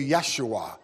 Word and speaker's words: Yeshua: We know that it's pronounced in the Hebrew, Yeshua.